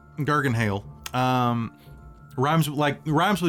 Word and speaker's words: Garganhale: Garganhale. Rhymes with Joel McHale.